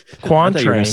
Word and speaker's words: quatrain: And he would just open these books to random pages, and 100% some of these Q-word poems, whatever the fuck it is, quatrums, is that how you say it? – Quatrain.